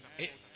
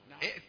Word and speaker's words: ائت: ائت نعم ائت اسمعهم ائت القوم الظالمين يعني لا تنطق الهمزتين